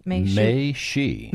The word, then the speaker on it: Mei Xu: Mei Xi. And it's M-E-I-X-U. So in China, M-E-I-X-U. In the Chinese phonetics, that's how it comes out.